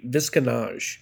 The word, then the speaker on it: Visconage: Also, yes, it is actually Visconage.